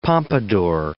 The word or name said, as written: pompadour